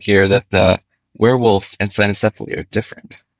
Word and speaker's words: cynocephaly: If you're using the restored classical Latin, it would be the cynocephaly. But we just say cynocephaly.